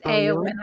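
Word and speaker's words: Éowyn: It doesn't sound like it would be pronounced like that, but it is actually pronounced Eowyn.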